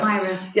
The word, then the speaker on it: Papyrus: And we decided to support Papyrus. I think that's the way to pronounce it, is it? – Papyrus.